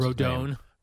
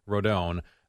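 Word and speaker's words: Rodón: No, I thought the Yankees and Rodone. Now we know that's how you pronounce Rodone. – Rodone. I never realized the tilde was over the O.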